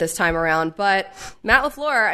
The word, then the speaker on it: LaFleur: Now I'm adding more syllables to LaFleur's name after Tom Pelissero did his whole thing on NFL Now about how it's three syllables.